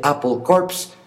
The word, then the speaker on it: Apple Corps: 23 de octubre, el famosísimo logo de la manzana de Apple Corps, pronunciado como Apple Corp, centro de la manzana, ya es propiedad de Apple Inc., la compañía estadounidense de tecnología.